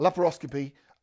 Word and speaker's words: laparoscopy: So he managed to get a ticket to go back, and just over a week ago, they did a laparoscopy. Is that it? I can't never say it properly. Laparoscopy. Is that it? Yep. A laparoscopy.